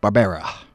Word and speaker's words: Barbera: you can do the tags and get the pronunciation, Barbera. – Barbera. Barbera. I was like Hanna-Barbera.